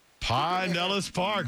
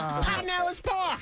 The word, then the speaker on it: Pinellas Park: Pinellas Park, very good. – That's the way it's pronounced, son. – Yeah. – Pinellas Park.